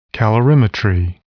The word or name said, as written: calorimetry